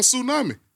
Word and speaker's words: tsunami: It's a T on tsunami.